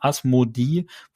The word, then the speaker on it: Asmodi: Im Englischen wird er jedoch Asmodee, also mit Doppel-E äh, genannt. Das wird dann ja im Englischen auch wie ein I ausgesprochen. Das heißt hier der deutsche Name Asmodi wurde einfach vom Englischen übernommen, obwohl es eigentlich eine deutsche Übersetzung gibt.